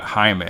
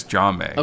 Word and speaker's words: Jaume: 2016, directed by, I believe his name is Jaume Colette Serra, not Jaime. I think it's Jaume.